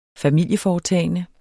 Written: familieforetagende

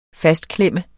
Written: fastklemme

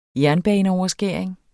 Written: jernbaneoverskæring

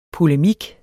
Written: polemik

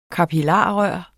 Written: kapillarrør

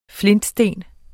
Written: flintesten

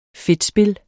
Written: fedtspil

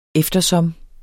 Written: eftersom

